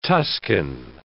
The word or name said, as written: Tuscan